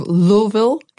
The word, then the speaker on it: Louisville: Out of Louisville, Kentucky. That's how my Kentucky friends say it is pronounced.